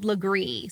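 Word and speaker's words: Lagree: It's called Legree. So I'll spell it for you. It's L-A-G-R-E-E.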